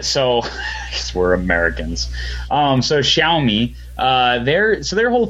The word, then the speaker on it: Xiaomi: Think of Xiaomi, pronounce the first word as if it were shower. Show what? The mystery ends here. According to the Xiaomi? – Xiaomi, that's right. Xiao. Technically, the first syllable is more of a Xiao than a show, but the difference is fairly subtle to most American Indians. So it's Xiaomi, that's right.